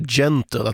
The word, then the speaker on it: gentle: Jag har suttit och funderat under låten och kommit fram till att jag tror man ska uttala det här gentle, att den här nian ska vara ett G, och jag är väl lite för fåfäng för att låta den där fel uttalet hänga ute i eten.